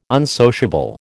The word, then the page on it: unsociable: American English